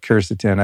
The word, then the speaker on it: quercetin: will contain that quercetin, or as you call it, quercetin. I think we have to agree that we pronounce things differently, tomato, tomato.